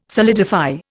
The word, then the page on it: solidify: Indian